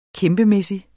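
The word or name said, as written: KÆMPEMÆSSIGT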